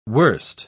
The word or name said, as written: worst